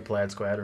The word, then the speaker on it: plaid: To become the plaid squad or the plaid squad. – Plaid squad. – You want to pronounce that? – Nothing rhymes with plaid. – Like rad? – Rad, yeah. Rad, Brad.